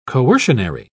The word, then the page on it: coercionary: British English